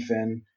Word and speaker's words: Fihn: Frankie Finn, last name is spelled F-I-H-N. It's an unusual spelling, but again, F-I-H-N.